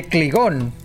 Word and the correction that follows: Klingon: Ah, cabrón, ¿cómo Klingon. – Klingon.